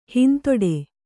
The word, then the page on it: ಹಿಂದಡಿ: Kannada